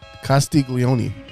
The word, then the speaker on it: Castiglione: How do you say this? Castiglione? – Castiglione.